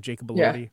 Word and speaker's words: Jacob Elordi: Elordi, is that how you say his name? Jacob Elordi? – Yeah.